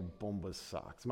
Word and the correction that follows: Bombas: Am I saying that right? Bomba socks? Is that how you say that? Anyways. Bombas socks. Okay. Well, we got that clarified, right?